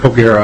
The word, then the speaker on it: Ogara: So, Mr. Ogara, I'm hoping I pronounced that correctly. – Yes, that's correct. – Thank you. – Rick Ogara, I'm the CEO and owner of Carl Bolander & Sons Company, a long-time company in Minnesota, 1924.